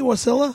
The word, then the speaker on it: Wasilla: Am I pronouncing it correctly, Wasilla? – Yeah, actually.